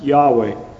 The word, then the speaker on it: Yahweh: And if you look at the margin in the Revised Version again, it will show you that that word Lord there is the Hebrew word Jehovah, or as it's more rightly pronounced, Yahweh.